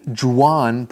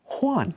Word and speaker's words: Juan: You know, because his name starts with the letter J. – Right. – And so some people might think it's Juan, but actually it sounds more like an H. Juan.